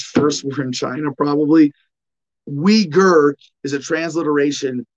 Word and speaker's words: Uyghur: And it's the same thing with the Uyghurs. And this is a really shitty transliteration because if you wanted to say Uyghur, I'd be like W-E-E-G-U-R.